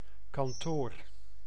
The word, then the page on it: kantoor: [kɑntoːɾ]; /kɑnto:ɾ/